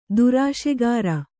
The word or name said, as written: ದುರಾಶೆಗಾರ